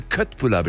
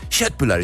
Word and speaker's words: köttbullar: wenn ich bei Ikea stehe und die Leute Köttbullar bestellen. Das sind ja Köttbullar, ich bin Halbschwede, das ist hier, die Aussprache ist ja fürchterlich. Erling Haaland ist falsch, ne? – Ja, das ist absolut falsch.